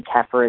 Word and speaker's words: kefir: Some people call it kefir. Apparently kefir is the correct pronunciation, so I keep trying to say it correctly.